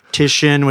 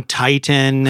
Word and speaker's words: Titian: So Titian, which is a Renaissance artist, I was calling Titan and I forgot all the other oh.